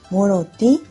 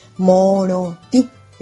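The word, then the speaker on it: morotĩ: Moroti, sabemos que lleva acento en la I. Moroti, entonces lleva acento en la I.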